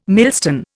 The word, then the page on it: millstone: Indian